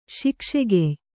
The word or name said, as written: ಶಿಕ್ಷೆಗೆಯ್